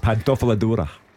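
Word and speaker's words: Pantofola d'Oro: The white boots, how do you pronounce it? Pantofola Dora? – That'll do. – Is that close? – Pantofola Dora. – Well done, actually.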